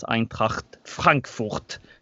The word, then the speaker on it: Eintracht Frankfurt: Eintracht Frankfurt. Vet inte om jag uttalade det där korrekt. – Gjorde du det? Så du råkar vara språkgeni också. – Ja, det var, det var alldeles lysande.